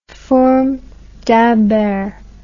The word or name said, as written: fourme d'Ambert